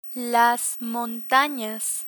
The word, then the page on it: montanas: Spanish